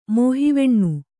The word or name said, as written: ಮೋಹಿವೆಣ್ಣು